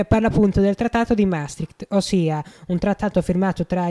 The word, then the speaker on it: Maastricht: Adesso invece parleremo del trattato di Maastricht. Tu sai Maastricht. – Cos'è? – Maastricht.